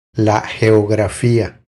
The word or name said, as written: La geografia